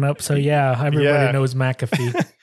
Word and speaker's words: McAfee: John McAfee. And some people were pronouncing it Mcafee, but I'm pretty sure it's McAfee.